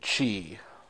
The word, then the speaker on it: ki: it's spelled K-I, so I say ki, but I think in the Japanese versions, they pronounce it chi.